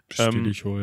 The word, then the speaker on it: Stilicho: Ähm, dann gibt es noch einen von 396 bis 398 unter Herrmeister äh, Stili Stilicho. Spricht man das so aus? – Stilicho, ähm, ja. Ja.